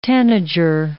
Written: tanager

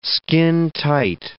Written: skintight

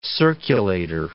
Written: circulator

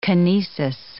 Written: kinesis

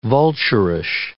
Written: vulturish

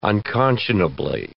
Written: unconscionably

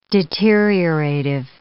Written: deteriorative

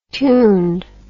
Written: tuned

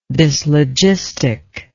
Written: dyslogistic

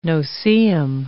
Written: no-see-um

